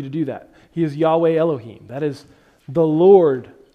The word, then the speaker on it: Yahweh: First of all, God's personal name, we believe, is pronounced more closely Yahweh.